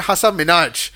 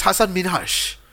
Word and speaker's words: Hasan Minhaj: People keep saying it Hasan Minhaj when it's Hasan Minhaj.